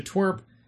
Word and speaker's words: TWRP: So Twerp, it's spelled TWRP, and that stands for Tupperware Remix Party.